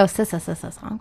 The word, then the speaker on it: Sssongs: It's like S-S-S-O-N. – You got to pronounce all of it. – Exactly. – Yeah. – Songs. Oh, is it Sssongs or Songs? – I would go Sssongs. – That's probably, yeah, that sounds better.